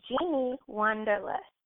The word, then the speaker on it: Jeannie Wanderlust: Did I say that right? Nope, it's Jeannie Wanderlust.